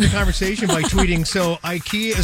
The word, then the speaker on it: IKEA: People were shocked the other day to find out that that's not the correct way to pronounce I-K-E-A. The actual pronunciation is IKEA.